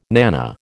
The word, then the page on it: nanna: American English